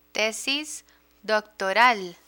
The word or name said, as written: tesis doctoral